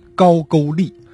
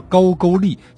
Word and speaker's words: 高句丽: 几十年后的公元三年，第二代王琉璃明王迁都到今天的吉林吉安，并且正式定国号为高句丽。勾呢就是句号的句，呃，一个多音字。